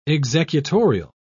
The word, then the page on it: executorial: British English